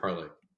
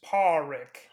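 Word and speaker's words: Pádraic: Parrick, or is it Parlick? – It's Parrick. – Parrick, okay. – Here, let me spell that for you. P-A with an accent, D-R-A-I-C.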